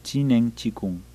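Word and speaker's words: Zhineng Qigong: Es Z-H-I-N-E-N-G, Q de queso I, G de gato O-N-G. Y se pronuncia Chinen Qigong.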